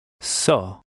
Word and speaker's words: Só: In number 12, the O has an acute accent over it, and here the accent changes the pronunciation of the O. Listen and repeat number 12. – Só.